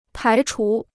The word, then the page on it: 排除: pái chú